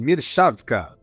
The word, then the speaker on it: Mirshawka: O nosso convidado é o Vitor Mirchavka. Falei certo, Vitor? Quase, Mirchavka. Mirchavka, agora eu aprendi.